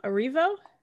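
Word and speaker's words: Erivo: How do you say her last name again? Please say it for me. Cynthia. Arrivo? – I think so. I think you got it.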